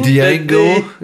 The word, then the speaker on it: Django: On prononce pas le J dans Django. – Django. – C'est Django.